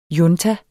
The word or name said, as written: junta